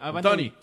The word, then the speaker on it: Toney: Es que allá está peleando con Toney. O no sé si se pronuncia así. – Tony.